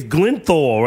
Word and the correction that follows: Gyllenhaal: right? How do you the way it's written is hard to fucking pronounce that shit. – Jake Gyllenhaal.